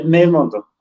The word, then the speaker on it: memondo: Ajá, entonces eh, se dice memondo. – Memondo. – memondo. Okay. Ajá, entonces como un acentito ahí medio rarito,